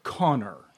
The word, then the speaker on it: Conchabar: To which I says to him, Master Scourge, I know the way it's spelled is C-O-N-C-H-A-B-A-R, but it's actually pronounced Connor.